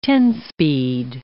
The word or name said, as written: ten-speed